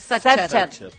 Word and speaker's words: Sacher: Sacher, Sacher. – Sacher. – No, Sacher. S-A-C-H-E-R. – Sí. – O ya me salió la torta. – Y es más, se suele escribir arriba, sí, Sacher.